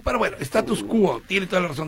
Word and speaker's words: Status Quo: Por acá Javier Leopoldo te corrige, dice, el nombre del grupo se pronuncia Status Quo, con O al final.